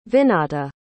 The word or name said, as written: vinata